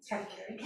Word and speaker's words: Katagiri: Katagiri.